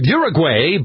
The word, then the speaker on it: Uruguay: First, how do you pronounce that South American country spelled U-R-U-G-U-A-Y? Many of us have always gone with Uruguay, but according to the pronouncer I found on YouTube, it's Uruguay. – All right, Uruguay it is.